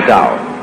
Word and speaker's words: Tao: So their principle of nature is called a Tao, T-A-O, pronounced Tao in the Mandarin dialect, Tao in the Shanghai dialect, To in the Cantonese dialect. Take your choice.